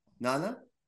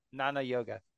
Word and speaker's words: Jnana Yoga: Nana? – Jnana Yoga. – What happens to the J? Is it just silent? – It's silent. Yeah.